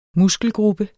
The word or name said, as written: muskelgruppe